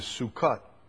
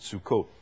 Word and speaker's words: Sukkot: And tabernacles or booths, or the word is Sukkot, Sukkot. It's how we normally pronounce it, Sukkot.